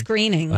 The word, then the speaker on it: Groening: It's not grow. – Looks like groaning. – But I think Matt, it's graining. – I think it's graining.